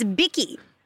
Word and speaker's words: Bicky: Is your name Becky, I'm realizing, but you pronounce it Bicky? – It's Bicky. So it's actually spelled B-I.